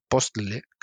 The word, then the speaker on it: Postle: que es Bruno Postle, creo que se pronuncia, P-O-S-T-L-E, que, ha hecho un programa que genera IFC, que genera IFCs válidos a partir de, 3Ds de volúmenes muy simples en Blender.